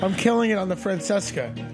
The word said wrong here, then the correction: Francesa: It's actually pronounced Francesa.